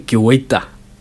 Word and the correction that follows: Guaita: Guaita. Guaita. Vicente Guaita.